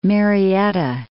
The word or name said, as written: Marietta